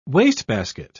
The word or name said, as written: wastebasket